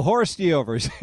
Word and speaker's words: hors d'oeuvres: Those are hors d'oeuvres, right? – Oh, horse deovers, yes.